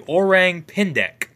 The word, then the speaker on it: Orang Pendek: In Sumatra, they have I really hope I pronounced this correctly, the Orang Pendek. – That's how you pronounce it.